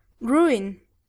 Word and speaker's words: ruin: Ruin. Ruin. – Very good. Ruin. Ruin. Pronunciamos la U y además la I.